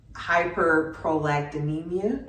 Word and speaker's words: hyperprolactinemia: So what that was is hyperprolactinemia. – Mm-hmm. – I said it correctly, right? – Yes.